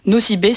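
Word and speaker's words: Nosy Be: Nosibé s'écrit N-O-S-Y espace B-E.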